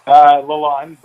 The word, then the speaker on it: Lalande: Lalande, but...